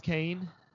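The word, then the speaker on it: Kayne: Only it's spelled like Kanye, Kanye, but pronounced Kane.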